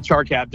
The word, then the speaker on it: Charcap: Yeah, Charcap, just like it's spelled.